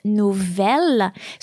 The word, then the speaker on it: nouvelle: Nouveau, N-O-U-V-E-A-U, that's the masculine form of this adjective, and its feminine form is nouvelle. So it's like beau and belle. Actually, it works exactly the same way. So nouvelle is spelled N-O-U-V-E-L-L-E.